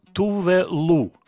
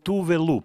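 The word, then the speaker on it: Tove Lo: Следующая артистка – Тувелу, шведка. Тувелу, пишется Товело. Кстати, по-шведски ло, лу читается. Тувелу.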